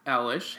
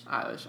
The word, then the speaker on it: Eilish: Also, Billie Eilish has got 9. – Eilish.